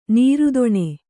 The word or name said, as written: ನಿರ್ಧನ